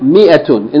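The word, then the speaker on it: Mi'atun (مائة): Okay, so Mir is one of them. The Aleph is written but it's not pronounced. That's all you really need to know about the way it is written. That the Aleph is written but it's not pronounced. It is pronounced as though there's no Aleph Mi'atun.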